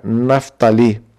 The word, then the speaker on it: Naftali: Esse acento no final é como os mais rigorosos aí com a questão da língua, né, costumam usar, né, esse acento final, esse acento na última sílaba, fica Naftali. É parecido com a nossa naftalina, mas na verdade não tem nada a ver, né?